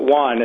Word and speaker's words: yuan: How do you pronounce the Chinese currency? – I would pronounce it yuan, and then there's, you know, there's a renminbi. There's another name for it. There's a couple names floating out, but I'd say the Chinese yuan.